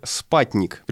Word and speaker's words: спутник: А теперь мое уточнение, пишет наш слушатель слушатель, многие люди в США произносят это слово как «спатник», предполагая, что буква «ю» передает звук «а», ну, как в английском.